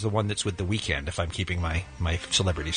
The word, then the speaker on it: The Weeknd: Meanwhile, the rest of the Hadid sister update, Bella, as being mentioned, is dating The Weeknd. – Weeknd. – Look, I'm looking at his name right here. – I know how it's spelled. I'm just saying that's how it's said.